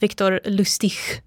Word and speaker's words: Victor Lustig: Man skulle kunna säga att Victor Lustig, som man ska uttala det då egentligen, var på piken av sin karriär när han säljer Eiffeltornet.